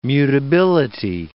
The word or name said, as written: mutability